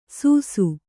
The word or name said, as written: ಸೂಸು